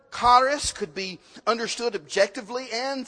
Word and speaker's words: charis: I'm trying to roll the R there. That's the way the pronunciation guide told me to say it. But charis, charis. You can say it that way. Charis, charis. I can't roll the R.